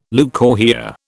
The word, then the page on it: leucorrhoea: British English